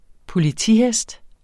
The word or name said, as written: politihest